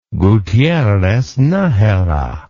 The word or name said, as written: Gutierrez Najera